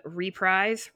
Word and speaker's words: reprise: Percy has another dream in the Weirdest Dream reprise. I never say it right. If I say it both times, one of them will be right. – Reprise is the noun. Reprise is the verb.